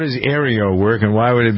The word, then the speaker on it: Aereo: There's a new technology called Aero, A-E-R-E-O. – Aero.